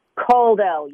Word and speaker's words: Kuldell: We're talking to Heather, you tell it, because am I pronouncing it right? Give her the background. – Kuldell, you got it. – Okay, Kuldell.